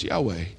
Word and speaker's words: Yahweh: So these four letters we think are pronounced Yahweh.